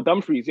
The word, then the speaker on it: Dumfries: Dumfries. – Oh, Dumfries, yeah.